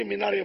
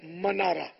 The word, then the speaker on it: منارا: تو وہی منارا عوام میں منارا کہا جاتا ہے, اب تو خواص بھی مینارے بولتے ہیں, ہو سکتا ہے غلط العام فصیحون کے زمرے میں آ جائے. بہرحال یہ کہ اس کا صحیح تلفظ وہ ہے منارا, میم پر زبر ہے,